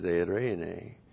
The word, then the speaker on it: eirene: See, Irene is eirene. Her name means blessing. Jerry, I tell you, he lucked out, didn't he? He married blessing. He married blessing. Fantastic. E-I-R-E-N. Oh, boy. E, long E. Eirene. And eirene is often translated peace.